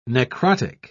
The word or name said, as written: necrotic